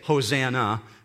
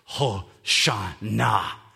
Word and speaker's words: Hosanna: You might be very familiar with it because in the English, we say Hosanna. It's very much an English pronunciation. But in Hebrew, it sounds like this, Hosanna.